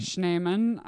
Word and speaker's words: Schneeman: shout out some kid tommy Schneeman actually is the correct German pronunciation.